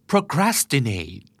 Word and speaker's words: procrastinate: procrastinate procrastinate ออกเสียงนะครับ pro crass procrastinate procrastinate stress พยางค์ที่สอง